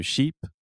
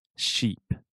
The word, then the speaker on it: sheep: So, sheep, Ovelia, has the sh sound, sh, sheep, and then jeep has the j, j, jeep.